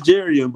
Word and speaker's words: Jerrion: It's Jerrion,